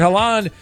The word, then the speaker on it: Haaland: Story number two, a rising star, Erling Haaland, am I saying that right, of Norway, has been the breakout star Haaland. – Of the World Cup. And his 15 minutes of fame apparently won't end when the tournament does. TMZ learned this week that Haaland will make his appearance in a much-anticipated comedy sequel, White Chicks 2.